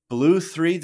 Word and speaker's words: Blu3: Blue3 DAO, it's spelled BLU3, I think you said Blue DAO, whose mission is to empower women and help them achieve financial freedom, is being accused of financial mismanagement.